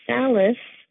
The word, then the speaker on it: Salas: And Salas rhymes with palace, is my married name, and that comes from my husband's father's family, which is Portuguese.